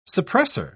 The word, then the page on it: suppresser: British English